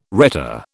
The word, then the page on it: rhetor: British English